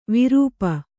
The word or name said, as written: ವಿರುಪ